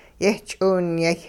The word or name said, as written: yehch'iwinyay